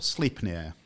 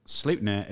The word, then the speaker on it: Sleipnir: Yep, or Sleipnir. You can pronounce it both ways. – I thought it was Munin. No, Munin is Raven. – That's right. So Sleipnir is Odin, the Allfather's eight-legged steed.